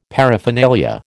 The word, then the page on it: paraphernalia: American English